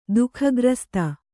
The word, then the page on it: ದುಃಖಗ್ರಸ್ತ: duhkhagrasta